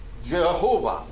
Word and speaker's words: Jehovah: In Latin, the Y and the J are pretty similar, and so it's actually true in many languages, Y and J have a similar sound and so on, and so ultimately we're going to end up pronouncing this Jehovah, which is really the Yahweh consonants with kind of a Latinized form of the Adonai vowels.